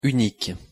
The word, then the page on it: unique: French